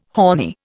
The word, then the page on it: horny: Indian